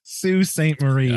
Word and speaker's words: Sault Ste. Marie: I think this is Sault Ste. Marie. – Oh, he got it. Sault Ste. Marie.